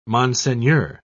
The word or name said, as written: monseigneur